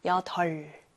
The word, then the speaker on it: 여덟: 그런데 덜 어떻게 읽어야 되나요? 겹받침인데 둘 중에 하나의 자음만 소리가 나요. 여덜 무엇인가요? 앞에 있는 자음 바로 리을이죠. 여덜 이렇게 소리가 납니다.